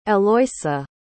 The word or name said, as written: Eloisa